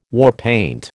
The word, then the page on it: warpaint: American English